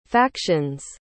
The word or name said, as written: faction's